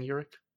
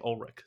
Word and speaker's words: Urich: Now, just a quick reminder, Tony, his name is Urich, right? Ben Urich? – Yes. – Okay, because his last name is spelled like Ulrich, and every time I see it spelled out in my notes, I want to say Ulrich. So, yeah, all right. – Well, you would be wrong.